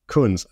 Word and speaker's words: Kunz: Kunz.